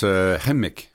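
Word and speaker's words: Kukum: on prononce « Hemik ». – Hemik, oh. C'est Elisabeth Isaac qui me dit « c'est comme des Arabes, Hemik ». – Hemik. – Ils mettent l'accent, c'est « Hemik » et non pas « Hemik ».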